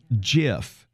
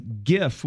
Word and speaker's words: GIF: Is it pronounced GIF or JIF? – Uh huh. – Well, I've always done GIF with a hard G because it stands for graphical, which starts with a hard G.